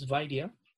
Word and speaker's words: Vaidya: And my last name is Vaidya, and it's spelled V for Victor, A, I, D for David, Y, A.